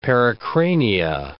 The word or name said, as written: pericrania